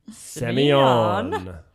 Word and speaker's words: Semillon: So yes, the grape of the week thrones is Semyon.